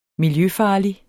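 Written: miljøfarlig

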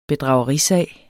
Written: bedragerisag

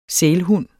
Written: sælhund

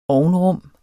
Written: ovnrum

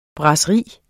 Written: brasseri

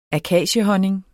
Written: akaciehonning

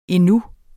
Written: endnu

